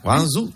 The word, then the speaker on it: Guangzhou: Guangzhou, hay que poner cara como de...